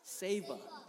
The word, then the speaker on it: Seva: Seva.